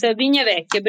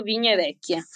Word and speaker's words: Vigna Vecchia: but I have read about the Vigna Vecchia, and I'm probably butchering that name. – Yes, yes, of course, Vigna Vecchia, Pico del Bosco, Vigna Vecchia.